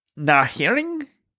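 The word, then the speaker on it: Naehring: While listening to music that's being played, Teddy gets another flashback to the camps, and we are introduced to Dr. Naring. – I believe the H is silent.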